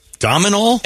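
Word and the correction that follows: Domhnall: It's pronounced Donal. – Donal Gleason. In his accent. Dumnol is what I've always heard.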